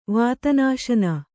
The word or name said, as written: ವಾತನಾಶನ